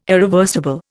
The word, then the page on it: irreversible: Indian